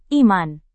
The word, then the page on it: iman: Indonesian